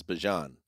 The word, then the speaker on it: Bijan: How you pronounce this, the SI, how you pronounce my name is Bijan. Like, that's how you say my name, he said. Told Fox 5 in Atlanta. But I say Bijan.